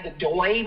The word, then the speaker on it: Godoy: I'm not sure how to pronounce his last name, but it's G-O-D-O-Y, Godoy, maybe? Godoy? And he has quite a few in his lineup.